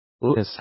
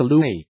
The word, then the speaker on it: Louis: 8 from Kensington Palace said the baby would be known as His Royal Highness Prince Louis of Cambridge, pronounced Louis, not Louis.